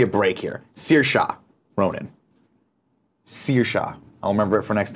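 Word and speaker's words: Saoirse: Industrial 86 says, "LOL, it's pronounced Searsha." I said Searshi.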